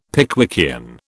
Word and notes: Pickwickian: British English

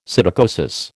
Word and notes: psittacosis: American English

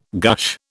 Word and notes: gush: British English

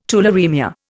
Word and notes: tularaemia: Indian

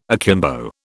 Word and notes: akimbo: British English

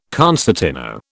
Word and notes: concertino: British English

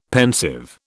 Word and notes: pensive: British English

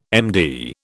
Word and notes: MD: British English

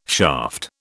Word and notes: shaft: British English

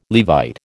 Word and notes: Levite: American English

